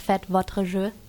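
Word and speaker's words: Faites vos jeux: Du hast es eigentlich schon richtig ausgesprochen, es heißt Fête Votre Jeu.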